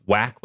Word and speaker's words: Whack: And I'm trying to remember the name of this character from Watchmen, but one of my favorite Twitter accounts, Whack with a silent H, at Whack Nicholson, posted.